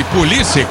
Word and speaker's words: Pulisic: Eles chamam de Pulisic, né? Mas nós vamos de Pulisic aqui, na nossa pronúncia fica bem melhor.